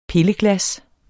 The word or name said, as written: pilleglas